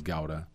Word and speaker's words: Gouda: like Gruyere or we call it Gouda, but I know the name is Gouda. From Holland.